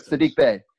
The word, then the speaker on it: Sadiq Bey: Yeah, the E sound at the end of Sadiq was throwing him off. – Yeah. Got you. Anyway, right. Sadiq Bey.